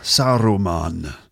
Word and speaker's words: Saruman: You hear that R? Saruman. Which is, again, that it's high received pronunciation. You might hear people rolling their R's like that. But it's also because Saruman is a word that was created by Tolkien, so it's sort of pronounced in that Tolkien elvish accent.